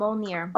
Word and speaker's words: Mjolnir: I forgot, honestly, I forgot that Vision held um, Mjolnir. Mm-hmm. Did I say that right? Yep. Mjolnir.